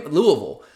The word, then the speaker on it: Louisville: So many Louisville. Oh. All like all of my friends that I talked to this week about that. Did you see that that we scare that we schedule Louisville?